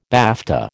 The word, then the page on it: BAFTA: American English